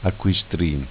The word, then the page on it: Acquistrin: Dialetto